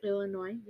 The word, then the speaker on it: Illinois: The United States, for the listeners, we have Florida, Virginia, Washington, Illinois. Is that how you pronounce it? – Illinois, yep.